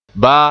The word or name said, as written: baa